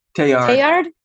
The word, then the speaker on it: Teilhard de Chardin: He has a French name, and I'm always worried that I'm saying it wrong, but it's like Teilhard de Chardin. – Teilhard. – Uh-huh. See, this is great. I feel like I should have asked you before. So we're going to teach everybody how to say it. So it's Teilhard de Chardin, okay. Right? – De Chardin. There you go.